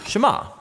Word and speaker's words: Shema: Now, the first word of the Shema is hear or listen, which in Hebrew is pronounced Shema.